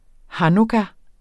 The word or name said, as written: hanukkah